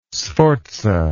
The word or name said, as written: Sforza